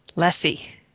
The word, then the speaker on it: Lessie: We have Chris Lessie. And I paused on the name because I wanted to make sure I got it right. But Chris, you tell me that it rhymes with messy. That's correct.